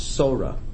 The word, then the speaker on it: Torah: It is the word itself is Torah, but in the context of this blessing, following the words divrei, the dogesh, the dot, and the saf falls out for grammatical reasons, and you pronounce the word Torah.